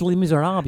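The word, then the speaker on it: Les Misérables: And read Les Miserables online. – It's Les Miserables. You don't pronounce the L-E.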